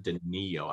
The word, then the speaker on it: Danilo: I want Danilo Gallinari to win this year. – Weird that you pronounce it Danilo. I always thought it was Danilo.